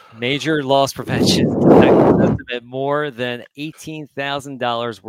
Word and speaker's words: Meijer: He left an area Meijer store. Did I say that right? – Meijer store.